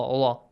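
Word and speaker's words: Allah (الله): The word, the letter lam in the word Allah should be pronounced with an a sound. Allah, Allah.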